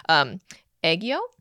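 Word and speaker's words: aegyo (애교): Okay, so sorry, I'm going to butcher the saying of this, but aegyo? – That was really good. – Oh, okay. – Wow. Yeah, aegyo. That's how you say it.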